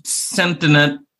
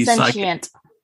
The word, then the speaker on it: sentient: So there's a tire and it in the middle of the desert, and it becomes sentient, I suppose is the right word. Also has the sentient. – What is it? – Sentient. – Oh, well, same word. I just didn't pronounce it as correctly.